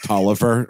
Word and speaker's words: Taliaferro: We've got one called that looks like on paper Taliaferro, but it's apparently pronounced Tolliver or something right.